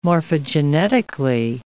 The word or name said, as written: morphogenetically